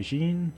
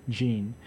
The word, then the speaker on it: Gene: And before that, you heard Olympian by Jean. It's spelled G-E-N-E. There's just like a lot of words that are pronounced the same. Jean.